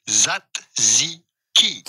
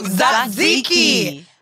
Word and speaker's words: Tzatziki: Tzatziki. – Told you. Tzatziki.